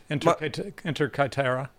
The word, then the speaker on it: Inter Caetera: Okay, on this day in 1493, Spanish Pope Alexander VI decrees in the papal bull Inter Caetera. Is that how you pronounce it? You know Latin. – Close enough. Inter Caetera.